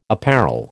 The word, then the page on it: apparel: American English